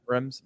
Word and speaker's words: Reims: And played well, scored a few goals in the championship, and now is going to, is it Reims, Charlie? Is that how it's pronounced? Reims? – Yeah. – Reims.